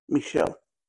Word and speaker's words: Michel: Michel or however you pronounce the name, Foucault. – Michel.